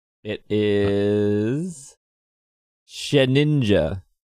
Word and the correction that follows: Shedinja: Well, except properly pronounced, it's Shedinja. Shedinja. There's a D in there.